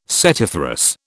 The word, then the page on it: setiferous: British English